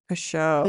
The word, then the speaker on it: Pechout: You gotta introduce yourself. How do we say this name? – Yeah, say the name. – Pechout. – Pechout. – Pechout. – But then it sounds different if my dad says it, and every announcer ever has always butchered it. So I just roll with whatever. Yeah.